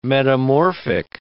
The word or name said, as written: metamorphic